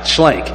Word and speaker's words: Schlenk: I said it right, Schlenk.